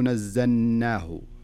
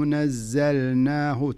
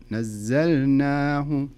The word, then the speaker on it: نزلناه: في قوله تعالى ولو نزلناه، زين لاحظ اللام، وش عليها اللام؟ عليها سكون، وسكون ظاهر بين معنى، معناها أنك لابد تنطق اللام. فلذلك لا تقول ولو نزلناه، ولو نزلناه، لا خلها اللام ظاهرة، ولو نزلناه، تنطق اللام ثم تنطق النون.